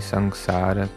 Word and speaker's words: sangsara: Third verse is Gopinath Kemone Shodibe More Najani Bhakati Kame Jaramati Poreche Sangsara Gore. And Sangsara, it's a samsara, it's the same word. This one is a Bengali pronunciation of the original Sanskrit word.